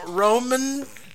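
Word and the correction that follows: Romijn: I think that's how you pronounced it. – Romaine. – Rebecca Romaine, at the time Romaine Stamos, mm-hmm.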